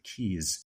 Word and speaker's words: Keyes: But it looks like it's pronounced Keyes, but it's pronounced actually Keyes.